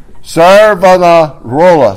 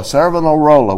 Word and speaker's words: Savonarola: Somebody want to pronounce that? Just throw it out there. Y'all are going to leave that to me, right? Savonarola. Savonarola. We'll say it that way.